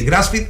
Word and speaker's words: grass-fed: Grass-fed es una palabra, no es que me ponga exquisito, digamos, lo que pasa es que se dice así, grass-fed, grass de pasto, feed de alimentación.